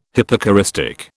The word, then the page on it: hypocoristic: British English